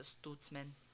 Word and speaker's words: Stutzman: Everything that I listen to, though, where people have, like, talked about him, like, on TV shows or anything, they pronounce it Stutzman. Eli Stutzman. Stutzman! Stutzman! – I mean, that's in German, that would be how it would be pronounced, but...